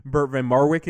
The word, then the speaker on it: Bert van Marwijk: And so in terms of the fact that now there's a Dutch manager in, before we get to Bert van Marwijk, am I pronouncing that correctly? – Yeah, that's as good as my Dutch is.